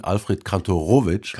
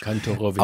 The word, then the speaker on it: Kantorowicz: Ja, der Name ist mythenumrankt und wird von allen, von Ihnen und bis jetzt auch von mir falsch ausgesprochen, Alfred Kantorowicz. – Kantorowicz. – Auf dem letzten O ist die Betonung, das lernt man in der Biografie, aber man lernt auch anderes.